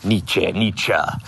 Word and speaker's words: Nietzsche: I never pronounce it quite right, but it's Nietzsche.